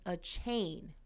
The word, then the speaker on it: Achane: And going back, apparently his name is pronounced Achane.